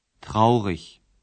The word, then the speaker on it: traurig: Neki se pridjevi prepoznaju po sufiksu i g. Izgovara se meko kao ich. – Traurig.